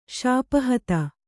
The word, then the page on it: ಶಾಪಹತ: Kannada